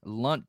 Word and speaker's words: Lundt: So some of these guys that the 49ers draft are going to have to be later picks, like a Chase Lunt. Am I saying that right? Is it Lunt? – I think so, yeah. – L-U-N-D-T, Lunt.